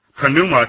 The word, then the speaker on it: pneuma: Well, there are Bible scholars that believe that the pi should be pronounced. That is, instead of pneuma, it should be pneuma.